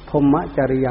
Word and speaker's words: พรหมจริยัง: คือพรหมจริยะออกเสียงบาลีว่าพรหมจริยัง